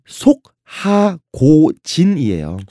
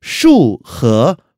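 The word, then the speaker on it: 束河古镇: 그런 다음에 리장 고성에서 한 북쪽으로 6km 정도 거리에 이름이 한국어 발음 속 하고진이에요. – 속하고진. – 예. 중국어 발음은 슈허 고진, 구전인데 이 슈허는요, 그러니까 아 구속하다, 속박하다 할 때 그 족자를 쓰고 그다음에 하는요, 황하 할 때 강하자를 씁니다.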